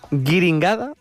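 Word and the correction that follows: Guiniguada: ya lo he dicho mal. Guiringuada.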